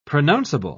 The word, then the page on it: pronounceable: British English